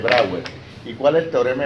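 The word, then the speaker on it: Brouwer: Se escribe Brauer, se pronuncia Brauer.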